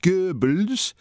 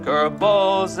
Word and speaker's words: Goebbels: excessively deferential to the Germanic pronunciation of Goebbels at the expense of a near rhyme.